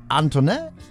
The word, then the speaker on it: Antoinette: Unter anderem DJ Antoinette, so ähnlich. Ne? So leicht französisch wird das ausgesprochen.